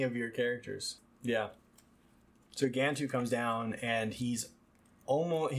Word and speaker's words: Gantu: Gantu. – Gantu.